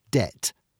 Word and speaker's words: debt: Take the word debt. In the 13th century, this could be spelt debt, dent, dete, or date. But 16th century writers looked to the Latin word debitum and inserted a silent B, linking the word to its Latin counterpart, but making it much harder to spell.